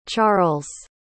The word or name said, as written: charles